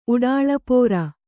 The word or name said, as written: ಉಡಾಳಪೋರ